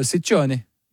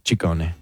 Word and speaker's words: Ciccone: Well, in Italian, it works the other way. So the double C's are hard C's and the singular C's are soft C's. – So Ciccione. – Ciccone.